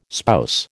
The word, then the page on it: spouse: American English